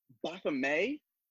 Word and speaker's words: Baphomet: They're French. The French pronunciation. Yeah. – Yes. It was very interesting the way they kept on saying Baphomet, Baphomet, Baphomet. And I'm like, I don't hear that T, but okay, sure.